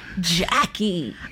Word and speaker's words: Jxcky: And that is actually spelled J-X-C-K-Y. – Oh. – I was like, when I looked at that, I was like, I don't know how to pronounce this. I'm so glad when you said it, I was like, that's how.